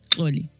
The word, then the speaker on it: Xolie: I was like, it means Zulu princess, Ndebele princess, whatever you want, you know, but it's pronounced Oli. Uh-huh. But I sometimes, you know, I don't know what I say, but they're like, oh no, you don't always say it like Oli.